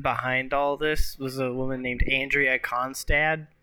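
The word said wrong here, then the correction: Constand: Konstand, that's how you say it.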